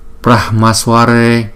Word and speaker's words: Brahmasware: Brahmasware Brahmasware. Thấy không? Chữ Brahma này là chữ H này chúng ta đọc có chữ H trong đó là Brahmasware Purne Purne.